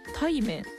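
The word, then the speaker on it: 鯛麺: こちらですね、徳島で食べられているタイ麺というメニューにちなんだこの乳麺なんですね、おお、タイ麺。 – はい、タイ麺、まあ普通に魚の鯛に、麺ね、うどんそばの麺の麺と書いてタイ麺と書くんですけども、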